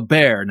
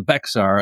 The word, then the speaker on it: Bexar: Okay, so the bear now, as a lot of us Yanks called it, the Bexar, until we were learned good on the pronunciation. So it's Bear after Bear County.